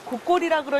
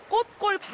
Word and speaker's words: 꽃골: 저는 처음에 곡골이라 해서 너무 무서웠는데 얘기를 들어보니까 저희 마을 골짜기 이름이 꽃골이래요. 근데 어른들이 꽃골 발음이 어려운 거라 그래서 꽃골이 곡골이 됐어요.